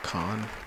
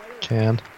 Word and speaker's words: Cannes: At Cannes. Sorry, Cannes. – Cannes. – Is that how you say it? – Yeah.